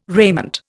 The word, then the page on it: raiment: Indian